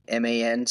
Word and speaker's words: Mackman: My last name is spelled Mackman, so Mac, like the truck man.